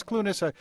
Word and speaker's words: Clunes: I thought it was Clunes. We'll try to get it right from now on. – Well, I think I mispronounce it. Clunes is the Scottish pronunciation, and it's a Scottish name, but I'm not Scottish, really.